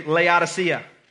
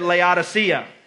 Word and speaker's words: Laodicea: Everybody say Laodicea. Some of you said it wrong. Say it one more time. The church at Laodicea.